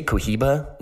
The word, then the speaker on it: Cohiba: So that is pronounced, or it's spelled C-O-H-I-B-A, but pronounced Cohiba.